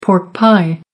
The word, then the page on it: pork pie: American English